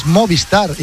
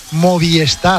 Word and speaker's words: Movistar: Y sepa, oye, y hay mucha gente que pronuncia mal Movistar, es Movistar, y la gente dice Movistar.